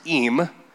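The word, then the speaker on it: eem (עם): The Hebrew word is eem, pronounced E-E-M.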